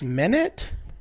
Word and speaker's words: Menat: I believe her name is pronounced Menet. M-E-N-A-T.